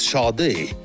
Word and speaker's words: Sade: Ça s'écrit Sad, S-A-D-E, comme le marquis, mais ça se prononce Sade.